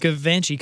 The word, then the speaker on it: Givenchy: Givenchy code.